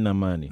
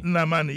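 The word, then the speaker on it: Nnamani: That's Ken Namani. – Namani, yes.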